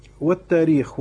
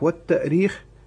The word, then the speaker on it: التأريخ: والتاريخ والتأريخ كقولنا القرآن والقرآن، يعني تنطق مهموزة وتنطق غير مهموزة.